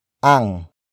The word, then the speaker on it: ang: Ang. Ang. Ang is spelled as A-N-G.